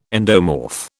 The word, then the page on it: endomorph: British English